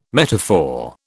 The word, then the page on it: metaphor: British English